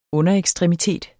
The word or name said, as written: UNDEREKSTREMITETER